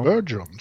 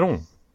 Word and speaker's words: Bergeron: Bit. Ja. Bergeron säger man. – Ja, och, nu är det mitt franska... Det kommer ett nytt uttal plötsligt. Bergeron. Nu är det franskkanadensiskt.